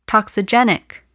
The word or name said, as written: toxigenic